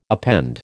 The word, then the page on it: append: American English